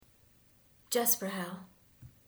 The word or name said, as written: Jesprechel